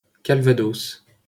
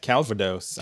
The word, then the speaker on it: Calvados: Okay, are you ready for this pronunciation? – Yes. – Calvados. – Okay, Calvados. All right, I was wrong.